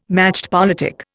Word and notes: machtpolitik: Indian

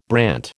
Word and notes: brant: American English

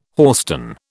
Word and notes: hoarstone: British English

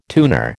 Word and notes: tuner: American English

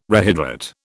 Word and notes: rehydrate: British English

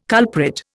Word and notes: culprit: Indian

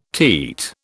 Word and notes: teat: British English

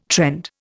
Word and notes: trend: Indian

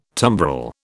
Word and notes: tumbrel: British English

